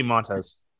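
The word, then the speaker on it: Montas: I know the Athletics reached arbitration with Montez, I think is how you pronounce it. – Yeah, Frankie Montez.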